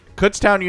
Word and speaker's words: Kutztown: For those of you that did not hear, so Kutztown University, for those of you that have never heard of it before, it's Kutztown. That's how people pronounce it, but it's Kutztown.